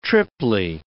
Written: triply

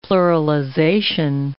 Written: pluralization

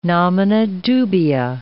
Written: nomina dubia

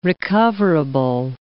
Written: recoverable

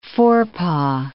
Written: forepaw

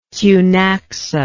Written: Cunaxa

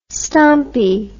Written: stompie

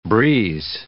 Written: breeze

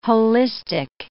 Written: holistic

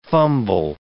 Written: fumble

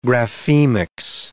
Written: graphemics

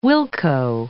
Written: wilco

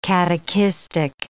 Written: catechistic

